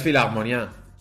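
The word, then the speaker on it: Philharmonie: filagmonia o, como, como, se diga. En teoría es filagmonia. ¿Filagmonia? Con un pequeño. – Ay, con una abierta, Agudo.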